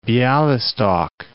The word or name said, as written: Bialystok